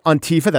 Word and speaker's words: Antifa: And we'll talk about Antifa, that's how you pronounce it, with a top academic who has studied them.